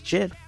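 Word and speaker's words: GIF: That's funny because I'm the person that pronounces Jit with a soft G, you know, right. – While everybody pronounces it Git. Well, the creator says it's Jit.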